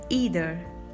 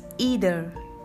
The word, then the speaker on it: either: In British English, we pronounce it as either, and in American English, we pronounce it as either. Focus on the R sound in American pronunciation. It's either, either.